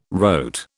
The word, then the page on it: rote: British English